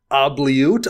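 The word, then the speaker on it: Oubliette: and that is the black enchantment Obliute, I believe it's pronounced. Something along those lines. – I think it's Obliute. It's got the accent on the E.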